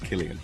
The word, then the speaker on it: Killian: It's an Irish name. It's an old Irish name. I think, like most Irish names, they were saints originally, and it's pronounced Killian.